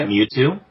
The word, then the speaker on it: Mewtwo: Uh, Mewtwo, is that how you say it? – Yeah, Mewtwo.